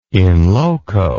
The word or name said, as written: in loco